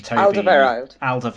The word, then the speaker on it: Alderweireld: Toby Alderweireld.